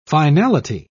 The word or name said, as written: finality